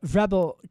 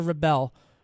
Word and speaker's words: Rebel: And she is a Democratic strategist and founder and CEO of uh, Rebel Communications in San Diego. Did I say that right? – Is it Rebel or Rebel? It's rebel, like the verb.